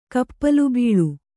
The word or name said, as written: ಕಪ್ಪಲುಬೀಳು